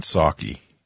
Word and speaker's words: Psaki: I think that the Department of Justice, it was interesting because, Jen Psaki, remember the P in Psaki is silent, just like the P in President Biden.